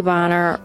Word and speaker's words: Bonner: She'd always issued her press releases with Hailey Bonner rhymes with honor, because people were always mispronouncing her last name like a certain slang term.